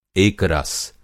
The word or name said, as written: एकरस